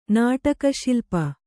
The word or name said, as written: ನಾಟಕಶಿಲ್ಪ